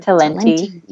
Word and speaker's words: Talenti: I know y'all like your, I don't know how you say it, Talento ice cream or Talenti.